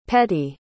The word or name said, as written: petey